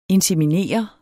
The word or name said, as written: Inseminerer